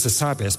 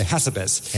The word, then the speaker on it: Hassabis: I think I'm right, it's Demis Hassabis, but they say Hassabis. – Yes. – And I say, no, you have to put emphasis on the right syllable.